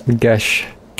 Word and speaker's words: geas: Calm Emotions, Charm Person, Charm Monster, Command, Detect Thoughts, Divination, Enthrall, Geas, Glibness. – Gesh. – Gesh is how that's pronounced? Oh, wow. Okay. – Yep.